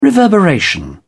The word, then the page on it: reverberation: British English